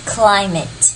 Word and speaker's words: climate: 但是很多时候我们会有这样的例外，像 mate 在后面这个地方，我们就把它念成 mit， climate。 Climate 就不用念成 climate，这些例子其实，你慢慢找，其实发现它还不少。